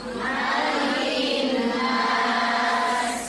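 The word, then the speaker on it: Malikin nas (مَلِكِ النَّاسِ): Bacanya bukan malikin nas tapi malikin nas. Itu bukan, ma-nya pendek. Kalau yang mim-nya panjang tuh maliki yaumiddin. Tapi kalau di surat surah An-Nas itu mim-nya pendek. Malikin nas.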